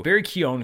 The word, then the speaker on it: Keoghan: Barry Keoghan, who is apparently our next Joker, which I'm glad that they were able to keep that under wraps.